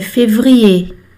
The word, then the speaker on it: février: Fevereiro é, se escreve com ER no final. Percebo muitas pessoas falando février. E é février. Esse é o acento.